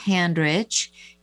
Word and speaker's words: Handrich: So that's under my name, Debby Handrich. Debby is spelled D-E-B-B-Y, and Handrich is H-A-N-D-R-I-C-H, Debby Handrich.